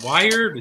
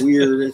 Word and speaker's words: wyrd: Is it wired? Is it weird? Is it weird? It's the Norse thing.